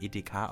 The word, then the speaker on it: Edeka: Kurz E.D.K. Und weil man das immer Edeka ausgesprochen heißt, heißt die Firma jetzt Edeka.